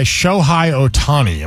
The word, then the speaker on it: Shohei Ohtani: highlighted in a deal by Shohei Ohtani. Am I saying that right? – Sure. Yeah.